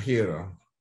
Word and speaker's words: Herro: Speaking of Heat, what does Jimmy Butler, who's playing lights out right now, and Tyler Herro, is that how you say it? Herro? – A hero.